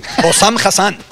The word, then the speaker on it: Hossam Hassan: Hossam Hassan.